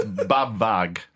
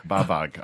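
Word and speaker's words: BAWAG: No, it's Babag. Babag, I think we oh yeah, on. The W is a V.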